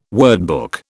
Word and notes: wordbook: British English

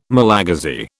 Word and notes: Malagasy: British English

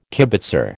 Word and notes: kibitzer: American English

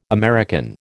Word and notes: American: American English

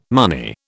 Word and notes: money: British English

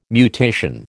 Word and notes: mutation: American English

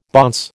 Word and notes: bonce: American English

